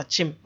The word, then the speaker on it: Akyem: I'm told that it's spelled Akyem, but it's pronounced Akyem.